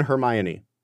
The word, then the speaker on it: Hermione: How many syllables in Hermione? Four syllables. Hermione.